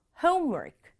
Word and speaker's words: homework: 그 발음할 때는 앞에 home 부분을 강조해서 homework. homework 이렇게 발음해 줘요.